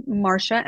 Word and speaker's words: Marcia: They can find me on LinkedIn, or my email is Marcia, M-A-R-C-I-A, at Chironomy.